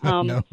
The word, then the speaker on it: TRHAP: How do you say that? TRHAP? – Hopefully it's not a trap, but TRHAP. So TRHAP.